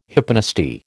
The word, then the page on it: hyponasty: American English